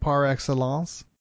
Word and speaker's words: par excellence: It might be more of a spelling one, maybe, than otherwise, because we pronounce this French expression pretty well, I think, par excellence. – Yeah, now you nasalized the end very well there. A lot of people can't handle that.